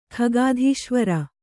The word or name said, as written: ಖಗಾಧೀಶ್ವರ